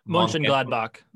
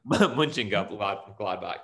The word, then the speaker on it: Mönchengladbach: Real Madrid could be knocked out if they can't beat Borussia Mönchengladbach. – Mönchengladbach.